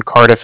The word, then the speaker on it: Cardiff: And I was calling with the Bank of Cardiff, and Cardiff is spelled C-A-R-D-I-F-F, as in Frank Frank, so it's like car and then different.